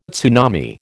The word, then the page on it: tsunami: American English